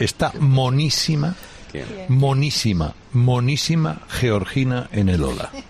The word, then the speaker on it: Georgina: Es que lo ha pronunciado como debe, Georgina. – Georgina, porque ella es de Jaca.